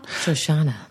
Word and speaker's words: Shoshana: I want to thank Shoshana. – Oh. – She's number one. – Shoshana. – Her name rhymes with banana.